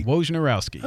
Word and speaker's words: Wojnarowski: Wojnarowski.